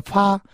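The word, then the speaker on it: 파 (八): 어, 팔자를 좋아하는 얘기는 중국은 이제 발음대로 이제 느낌대로 그 의미를 이제 가지고 있는데 중국 그 팔자 발음이 이제 파입니다. 파. 어, 파인데 파가 이제 그그 그, 그 중국의 이제 재물을 얻는다는 그 중국 그 글자하고 발음이 똑같습니다. 그래서 팔자 하면 돈을 번다라는 그 행운의 의미로 팔자를 참 좋아해서 중국은 한국 사람들은 이제 행운의 숫자로 칠자를 좋아하죠.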